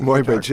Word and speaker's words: Muybridge: Why in your book, is it Muybridge? Is that how you pronounce it? – Muybridge, yeah.